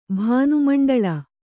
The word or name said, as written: ಭಾನುಮಂಡಳ